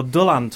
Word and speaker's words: Dylan: you mentioned, just at the end of the last show, when we were sort of musing about what we might do, you mentioned Dylan Thomas, or Dylan Thomas, for our Welsh listeners.